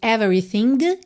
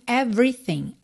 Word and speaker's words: everything: Atenção em everything, que a gente tem duas letrinhas pintadas de cinza, claro, porque elas não são pronunciadas. Então, a gente não diz everything, e sim everything.